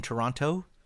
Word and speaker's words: Toronto: Where they don't pronounce the T in Toronto? – How do they say it?